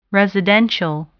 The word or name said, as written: residential